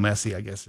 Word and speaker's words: Messi: By the way, if you are a soccer fan, Lionel Messi, or Lionel Messi, I was told that's how you pronounce it.